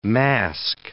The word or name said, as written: masque